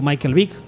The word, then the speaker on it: Michael Vick: Y en Philly empezó el partido Michael Vick, ¿lo digo bien? – Sí.